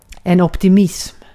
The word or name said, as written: optimism